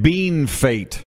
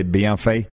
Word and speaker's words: Bienfait: Rick Haglin watching says, Macoun, McCoon and Bienfait are the most mispronounced Saskatchewan town names. Yeah, I think you're supposed to say bien fait. And we in Sask say bien fait.